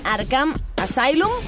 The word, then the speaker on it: Arkham Asylum: Esta semana tenemos Batman Arkham Asylum, lo digo bien.